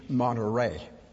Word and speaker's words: Monterrey: I better pronounce it correctly. In English, Monterrey.